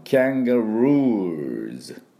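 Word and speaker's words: kangaroos: だからカンガルーじゃなくてキャンガルーズ、ね、ルーって引っ張って、そこにウー、ね、そこにアクセントで、最後はズ、 Z の音になります。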